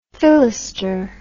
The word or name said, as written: fillester